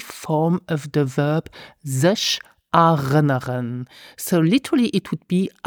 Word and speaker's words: erinnern: Of course, we will start with step number 1 and practice the correct pronunciation of the verb remember, which is erinnern.